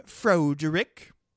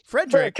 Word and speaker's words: Frederick: And he's like, and then he goes, "Is your first name Froderick?" It's like, "No, Frederick."